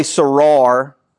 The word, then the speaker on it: sarar: You would say sarar in English is how we would pronounce it.